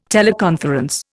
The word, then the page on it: teleconference: Indian